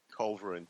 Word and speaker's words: Culverin: And then Cutlass's offsider, so to speak, or in the reverse, Culverin, is that how you pronounce it? – Culverin, yeah.